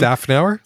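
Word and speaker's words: Szafnauer: Safnauer? – There you go.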